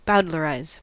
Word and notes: bowdlerize: American English

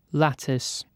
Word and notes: lattice: British English

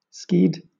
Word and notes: skied: British English

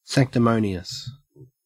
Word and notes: sanctimonious: Australian English

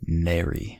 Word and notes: nary: American English

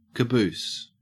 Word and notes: caboose: Australian English

